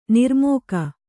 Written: ನಿರ್ಮೇಘ

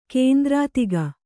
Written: ಕೇಂದ್ರಾತಿಗ

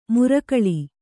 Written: ಮುರಕಳಿ

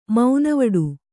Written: ಮೌನವಡು

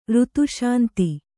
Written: ಋತುಶಾಂತಿ

